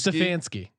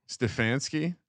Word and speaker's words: Stefanski: Stefanski. Stefanski.